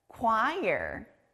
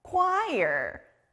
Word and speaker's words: choir: 이 다음 단어도 K 소리가 나요. 바로 합창단 choir이에요. 앞에 K 소리 들렸나요? 여러 사람들이 모여서 아름다운 노래를 부르는 합창단 영어로 choir. choir. choir.